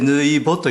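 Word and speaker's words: 抜穂: 抜き穂、はい、それでぬいぼと読むんです。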